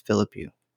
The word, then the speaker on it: Philippou: I think it's Philippou. – Just fake it till you make it. Yeah. That was right.